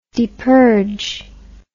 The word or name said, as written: depurge